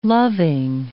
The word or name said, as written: loving